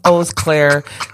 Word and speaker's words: Eau Claire: I went to a music festival in Eau Claire, Wisconsin, and I said Euclid like 8 times, and it's pronounced Eau Claire, and I sound like a goober, but yeah, it's pronounced Eau Claire.